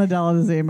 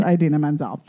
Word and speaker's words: Idina Menzel: It's not Adela Dazeem, by the way. That was John Travolta yes. saying her name wrong. It's Idina Menzel.